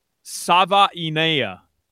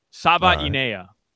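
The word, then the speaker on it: Savaiinaea: I've heard a couple different pronunciations. Here's what I'm rocking with: Savainea. Savainea.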